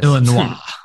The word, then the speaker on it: Illinois: You annoy me with your pronunciation of Illinois. – What do I say? Illinois? – Yeah, you don't pronounce the S. – Yeah, I know. It's a mess. – Illinois.